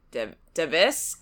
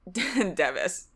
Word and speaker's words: Device: It looks like device, but I think we went with Devis.